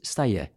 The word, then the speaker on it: Stedje: Og det blir skrevet Stedje, mm. – men det skal uttales Steie, akkurat som feie.